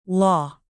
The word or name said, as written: law